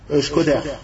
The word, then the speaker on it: Shkoder: Al-Ashkudari, Afwan, qui est une ville en Albanie, la ville où il est né, Cheikh al-Albani, yani qui est dit en français, Shkoder. Yani ça s'écrit S-H-K-O-D-E-R.